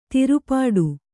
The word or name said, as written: ತಿರುಪಾಟ್ಟು